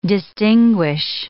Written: distinguish

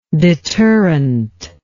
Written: deterrently

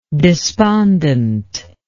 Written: despondently

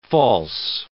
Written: false

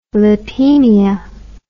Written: lipaemia

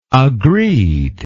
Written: agreed

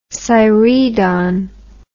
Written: siredon

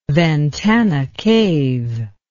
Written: Ventana Cave

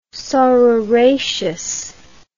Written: saururaceous

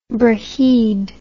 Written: braeheid

